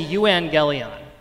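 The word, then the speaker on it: euangelion (εὐαγγέλιον): Let me hear you all say euangelion. Excellent. That's a hard one. That's really good pronunciation. Evangelion.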